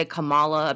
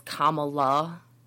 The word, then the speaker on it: Kamala: What's actually happening is I'm getting ready for this election and everybody defending Kamala. I keep having to hesitate to say her name now because I do actually want to say it properly, but I keep wanting to say Kamala. Apparently it's Kamala.